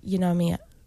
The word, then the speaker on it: pneumonia: Conjunctivitis or pneumonia? How do you say it? – Pneumonia. – Pneumonia. – Pneumonia. – Ignore the P.